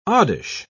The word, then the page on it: oddish: British English